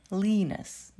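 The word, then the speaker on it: lenis: и leanness. Да, кстати, обратите внимание, что а, и буква и читается как в алфавите в этом слове.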